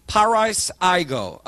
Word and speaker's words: pareisago: P-A-R-E-I-S-A-G-O. Long O. Pariseago.